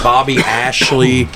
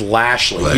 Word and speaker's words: Lashley: She goes, his name's Bobby Ashley or Bobby Asby or something. His name's Lashley. He was their reigning world champion.